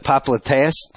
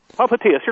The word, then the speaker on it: popliteus: The popliteus tendon? – Popliteus, you're close. – Popliteus tendon. – You went with the Latin pronunciation there. That's okay. That's acceptable.